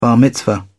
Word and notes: bar mitzvah: British English

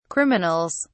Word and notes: criminals: British English

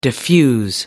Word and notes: diffuse: American English

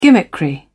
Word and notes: gimmickry: British English

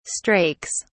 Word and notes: strakes: British English

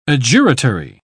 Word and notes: adjuratory: British English

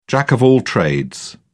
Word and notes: jack of all trades: British English

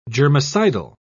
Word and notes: germicidal: British English

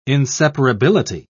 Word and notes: inseparability: British English

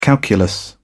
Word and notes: calculus: British English

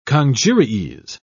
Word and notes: congeries: British English

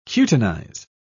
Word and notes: cutinize: British English

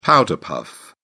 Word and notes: powder-puff: British English

